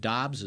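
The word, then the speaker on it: Doubs: Their wide receiver, Romeo, is it Dobbs? Is that how they pronounce it? – Yes.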